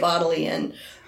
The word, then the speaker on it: Bodleian: I'm about 88% positive that's how it's pronounced. So I watched a lot of videos, and everybody seemed to say Bodleian. And so that's what I'm going with.